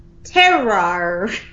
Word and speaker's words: terroir: I'm going to mispronounce this, or maybe I'm not. It's called terroir. Terroir. Terroir. T-E-R-R-O-I-R.